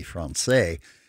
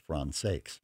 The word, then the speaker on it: Françaix: His last name ends with an X, and in French you would normally not pronounce it. It would be Français, but I'm told that the family pronounced it as Françaix.